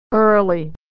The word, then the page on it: early: American English